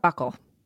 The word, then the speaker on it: buccal: Do you know how to say that for Kimball's DNA? Is that how you say it? Buccal. – It's a buccal.